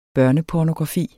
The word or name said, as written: børnepornografi